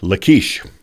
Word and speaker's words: Lachish: One of the cities he conquered was a city called Lachish, or I used to call it just Lachish, but the proper pronunciation is Lachish.